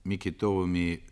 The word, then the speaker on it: Микитовыми: Имя Ивана Никитича Микитова, Микитовыми с ударением на последнем слоге кликали нас в деревне, было широко известно.